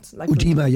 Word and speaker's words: Lymphoedema: Lymphoedema, is that what it's called? – Lymphoedema, yeah.